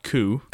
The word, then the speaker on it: Coup: So the first one is called Coup. Right? And that's a card game. – Spelled like Coop.